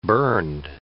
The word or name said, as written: burned